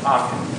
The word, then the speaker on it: Achan: I'm sure it was pronounced Achan. – Yeah, we say Achan.